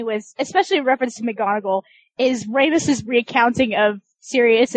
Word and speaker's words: McGonagall: McGonagall, but. – McGonagall, yeah.